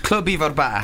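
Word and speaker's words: Clwb Ifor Bach: I'm not pronouncing it right. Club Ivor Back. – Club Ivor Back. – There you go. Club Ivor Back. I'm still working on the Welsh accent.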